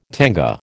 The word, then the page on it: tanga: American English